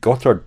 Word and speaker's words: Gotthard: The cycling podcast Gino Mader won the final stage, which went over the Gotthard Pass.